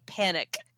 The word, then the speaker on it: Panik: But it's that weird looking guy and it's spelled P-A-N-I-K, panic.